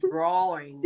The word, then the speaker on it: drawing: Drawing. – Drawing. – Take out the L.